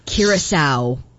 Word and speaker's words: Curaçao: Tiny little Curaco. – Curaçao. I think it's how you pronounce that. – Oh. – Curaçao. – Is it? Yeah. Okay. Well, you would know better than me. – No, but maybe you would know better than me. I think it's pronounced Curaçao. – Okay. – Well, somebody I don't know. There's been a lot of debates about pronunciation. – Confirmed, I've been there. It is Curaçao.